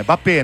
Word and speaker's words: Mbappé: É Mbappé. Não tem um M. É Mbappé. – É Mbappé. É muito mudo esse M, né?